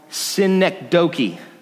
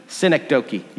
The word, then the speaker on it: synecdoche: Yeah, most scholars believe this was a poetic device, and I'm not going to be able to say it right. I wrote it down, okay, so I can say it right. And it's a poetic device called synecdoche. Synecdoche.